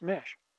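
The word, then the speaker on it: Meche: I think it's C-H-E, M-E-C-H-E. And it's pronounced Mesh. M-E-S-H, as a normal spelling might be.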